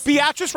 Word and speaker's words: Beatrice: Beatrice rhymes with mattress.